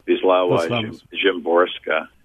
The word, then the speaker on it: Wisława Szymborska: Wisława Szymborska.